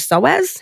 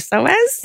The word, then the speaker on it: psoas: But that's where there's this amazing muscle that you might know about called your psoas. Have you heard of your psoas? – Tell us. – So it's spelled P-S-O-A-S.